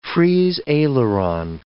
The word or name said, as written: Frise aileron